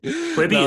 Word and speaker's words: Quibi: Quibi, is that how you pronounce it? I don't even know how to pronounce it. – Yeah, that's how how everybody... I know pronounces it.